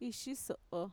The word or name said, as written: ishiso'o